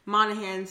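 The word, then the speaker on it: Monaghan: Monahan? – Yep. – The G is silent. – Okay. Monahan says that he's read all of the episode 9 script and he thinks it's amazing.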